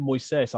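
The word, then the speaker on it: Moisés: They call it Moises. I thought they might split the vowels up, but Moises and then Caicedo.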